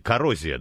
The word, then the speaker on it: Коррозия: Спрашивают, что с проектом Коррозион? Коррозия, да, правильно говорить? – Да, просто коррозия.